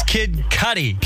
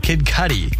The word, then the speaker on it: Kid Cudi: It's Kid Cudi. Kid Cudi.